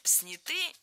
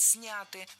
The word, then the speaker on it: сняты: А вот здесь уже ошибка в ударении. Сняты – неверно. Правильный вариант – сняты. В этом слове ударяем первый слог. Они сняты. Запомнили.